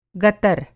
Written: गतर

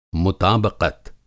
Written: मुताबक़त

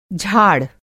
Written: झाड़

